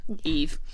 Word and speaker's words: Yves: So Eve is named after Sylvain's brother, Eve. It's spelt like Yves Saint Laurent. – Yes. – Y-V-E-S.